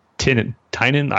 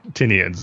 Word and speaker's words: Tynion: introduction to the podcast and it was pronounced uh, Tynion. I'm probably still pronouncing it wrong, but it's definitely not tin, Tinian. So, uh, but it was a great interview.